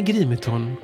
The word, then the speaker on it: Grimeton: Radiostationen i Grimeton, uttalas Grimeton, är ett industriminne som sedan 2004 står på UNESCOs lista över världsarv.